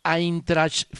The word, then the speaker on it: Eintracht: Nos recuerda que el diptongo EI se pronuncia AI, o sea que no es Leipzig, sino Leipzig. Y no es Eintracht Frankfurt, sino Eintracht Frankfurt.